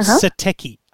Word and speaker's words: Satechi: So the brand I recommend is called Satechi, S-A-T-E-C-H-I, Satechi.